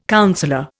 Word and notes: councillor: Indian

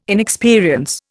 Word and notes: inexperience: Indian

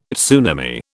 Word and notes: tsunami: British English

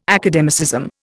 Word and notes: academicism: Indian

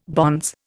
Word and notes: bonze: Indian

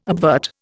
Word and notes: obvert: Indian

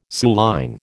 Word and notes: suilline: American English

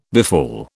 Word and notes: befall: British English

